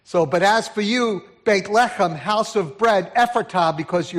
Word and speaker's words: Beit Lechem: And he writes, But as for you, Bethlehem, uh, Bethlehem if in Hebrew should be pronounced Beit Lechem, and it means house of bread.